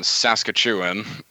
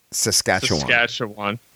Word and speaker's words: Saskatchewan: So the most recent one was 215 unmarked graves in British Columbia, 751 in Saskatchewan, and 182 last week, again in British Columbia. – That's Saskatchewan. Yeah, Saskatchewan.